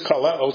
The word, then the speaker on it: kaleo: The word is kaleo. It sounds kind of like the word call to us, doesn't it? Spelled like a K instead of a C. Okay? Spelled K-A-L-E-O, long O. Okay, kaleo, the accent's here.